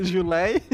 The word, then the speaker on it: July: E aí eu odiava, primeiro, porque não se pronuncia Julie, se pronuncia July.